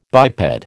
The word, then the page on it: biped: American English